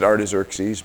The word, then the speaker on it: Artaxerxes: I pronounce it Artaxerxes, but Artaxerxes, who was king of the greatest empire of the world at the time.